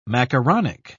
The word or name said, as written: macaronic